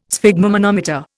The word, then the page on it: sphygmomanometer: Indian